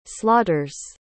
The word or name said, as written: slaughters